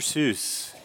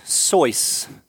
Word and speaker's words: Seuss: Dr. Dr. Seuss. Yeah. But apparently that's wrong. You see, his name is actually pronounced Soyce, rhymes with voice or noise.